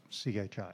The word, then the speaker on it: Qi: Is that QI or CHI? – QI. – QI, okay. – Pronounced CHI. – Like the British game show thing, yeah. – They say, nope. They say it's a Chinese word.